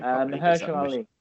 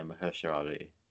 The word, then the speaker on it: Mahershala Ali: Mahershala Ali. – Yeah, Mahershala Ali.